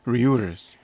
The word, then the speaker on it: Reuters: So, according to Retours? Retours? How would you pronounce that? I'm from the homeland. – Reuters. Yeah.